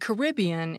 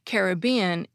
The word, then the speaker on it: Caribbean: A 2010 book on pronunciation says that Caribbean is more common among British speakers, and Caribbean is more common among American speakers. But my reading of the OED shows that they say Caribbean is also more common among British speakers.